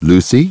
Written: Lucy

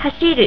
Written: hashiru

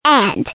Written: end

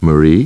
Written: Marie

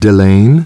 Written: Delane

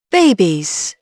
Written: babies

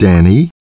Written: Danny

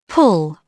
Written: pull

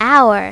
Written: our

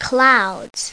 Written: clouds

